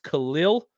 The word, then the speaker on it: Khalil: Let's talk about, it's pronounced Khalil.